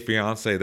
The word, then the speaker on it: Fiancé: Is that how you say it? Is that correct? – Yes, 90 Day Fiancé.